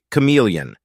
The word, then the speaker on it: CM3leon: Today we are talking about Meta's new generative model for text and images, CM3leon, pronounced like chameleon.